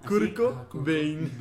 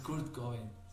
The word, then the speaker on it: Kurt Cobain: Kurt Cobain. – Curco, Curco. – ¿Qué? ¿Algo dije mal? – No, no, es que una vez alguien escribió como Curco. – Curco Bain.